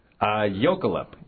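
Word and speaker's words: Jökulhlaup: How do you say that? – Jokulhulp. – Jokulhulp. – Yeah, that's sort of my best American pronunciation of that word. I'm sure in Icelandic it's pronounced a little differently, but, Jokulhulp, yeah.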